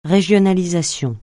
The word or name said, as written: régionalisation